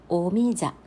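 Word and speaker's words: オ ミ ジ ャ (오미자): オミジャというふうに韓国語で発音するんですが、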